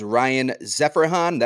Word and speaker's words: Zeferjahn: It is Ryan Zeferjahn.